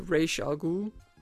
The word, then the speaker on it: Ra's al Ghul: Og hvad der også ligner Ra's al Ghul. I hvert fald, fordi det er jo sådan, man udtaler det, folkens.